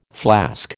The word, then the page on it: flask: American English